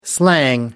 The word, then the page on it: slang: American English